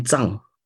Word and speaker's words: Tsang: So it's been, you know, growing up with this name has kind of been a very interesting experience, because that's not how my dad pronounces my name. He says it's Henry Tsang. And I say it's Henry Tsang. That's how my teachers tell me.